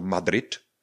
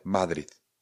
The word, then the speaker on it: Madrid: Nicméně, my klidně říkejme plnohodnotné D, nicméně mějme na paměti, že D ve španělštině je měkčí, to znamená, není to Madrid, ale Madrid. Madrid. Ten jazyk se nám opravdu víc přiblíží k tomu hornímu patru.